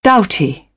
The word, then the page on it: doughty: British English; t